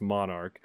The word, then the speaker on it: MNRK: A divestiture of the music interests of E1 was made to the Blackstone Group, quote unquote, and subsequently the name was changed to MNRK, pronounced Monarch.